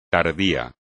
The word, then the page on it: tardío: espagnol